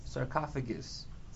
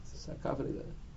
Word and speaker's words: sarcophagus: Yeah, how do you pronounce it? – Sarcophagus. – Sarcophagus. That's what it's called.